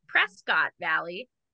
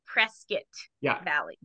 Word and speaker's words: Prescott Valley: And then that was Matt lived down in Arizona in what I called Prescott Valley. It's not. It's Prescott Valley.